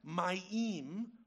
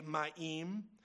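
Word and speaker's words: mayim: Part B of the rhyme scheme is the word for water, ma'im, which rhymes with God, Elohim.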